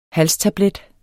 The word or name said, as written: halstablet